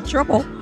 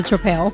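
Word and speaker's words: Tripel: I had the Canadian Belgian Triple. It's not a triple, it's a trapel.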